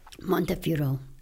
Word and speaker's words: Montefiore: And there's also, what's the name of Mr. Montefiore? Am I saying that right? – Montefiore.